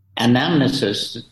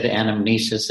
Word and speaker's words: anamnesis: And anamnesis, our hearers might get confused because there's two pronunciations of the word. You just said anamnesis and I say anamnesis. I think everybody in English says anamnesis, but I just have this Italian thing in me. That's the way it's pronounced in Italy.